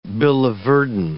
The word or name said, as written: biliverdin